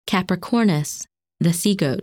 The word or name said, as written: Capricornus